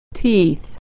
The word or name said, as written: teeth